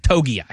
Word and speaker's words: Togiai: Is it Tongai? Is that how you pronounce his last name from the Texans? – Togiai. Yeah.